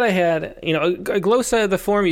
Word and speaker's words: glossa: But the prompt for this week was to write a glossa or glossa. I still don't know how to pronounce it. But in her poem, I realized that Diane Seuss pronounced it glossa. So we'll go with glossa.